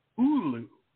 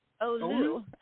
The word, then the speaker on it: Oglu: I got to remember, no hard G, no G at all. Oglu. – Oglu.